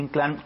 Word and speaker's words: Inclán: Los quiero invitar a que nos sigan ahí, a Julio Inclán, mi nombre completo, nada más que fíjese que es el de Julio Inclán con acento en la A.